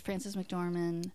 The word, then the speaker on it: Frances McDormand: It's Frances McDormand. Is that how you say her name? – Yeah.